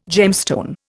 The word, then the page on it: gemstone: Indian